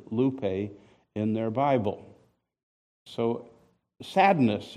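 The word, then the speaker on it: lupe (λύπη): You don't need to know the Greek letters because there it is in English letters, lupe. And it even tells you how to pronounce it, lupe.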